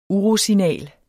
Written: UROSIGNALER

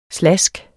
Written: slask

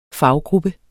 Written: faggruppe

